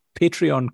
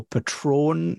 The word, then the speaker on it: Patreon: the, the amount of times that in my head that I go Patron. No, it's not.